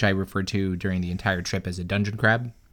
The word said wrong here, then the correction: Dungeness: And I think it's a Dungeness crab. It is how it's pronounced. – Dungeness? – Dungeness, yes.